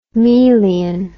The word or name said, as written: melian